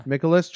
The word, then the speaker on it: Mikolas: Mikolas, 26, Dakota Hudson, 26, Adam Wainwright, 24.